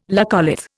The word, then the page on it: laccolith: Indian